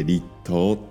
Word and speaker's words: 立冬: 立冬というのは冬が立つって書いて立冬って読む